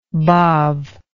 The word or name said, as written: badb